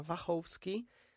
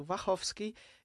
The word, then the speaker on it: Wachowski: No i oczywiście za sterami całego filmu, za reżyserię odpowiedzialna jest Lana Wachowski. Tak to się wymawia po angielsku, po polsku można powiedzieć po prostu Wachowski.